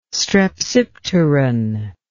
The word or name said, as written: strepsipteran